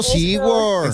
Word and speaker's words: SeaWorld: Sí, SeaWorld, sí sabe qué es eso, ahí me llevó y a los delfines pude tocar un delfín, ¿sí se dice así? – Sí. Está muy bonito, SeaWorld.